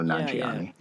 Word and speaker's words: Nanjiani: I believe so, Nanjiani.